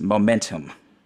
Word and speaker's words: MMNTM: it's spelled MMNTM, and my simple rap brain was like, oh, it's then logic hit me and I realized it's momentum.